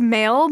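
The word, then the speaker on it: M(h)aol: The band is called Mail, but it is not spelled the way that you would think. It's spelled... – So it's spelled M-A-I-L, right? – Yeah, exactly, like email. it's spelled M and then the letter H in parentheses and then A-O-L, Mail.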